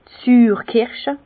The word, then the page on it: d' Süürkirsche: pl.; Bas Rhin; Herrlisheim